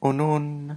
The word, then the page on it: unun: Lwaitel